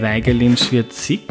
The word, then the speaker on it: Weigelin-Schwiedrzik: Mit dabei ist Susanne Weigelin-Schwirzig.